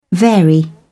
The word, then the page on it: vary: British English